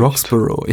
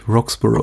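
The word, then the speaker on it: Roxburgh: Roxborough. Ich hätte jetzt wie Edinburgh ausgesprochen, nämlich Roxborough. – Okay, weil dann nur B-U-R-G-H am Ende ist.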